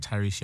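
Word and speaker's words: Tyreece: Oh, at Tyrese Younger on anything.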